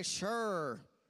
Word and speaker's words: Shur: Everyone say Shur. Come on, say it like you're from southern Indiana. Shur.